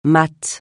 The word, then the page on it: mat: francais